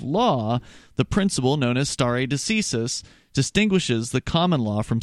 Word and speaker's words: stare decisis: He pronounced it, and I did look it up. It's stare decisis. It's stare decisis. – Latin, and in Latin it means let the decision stand.